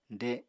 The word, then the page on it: de: apatani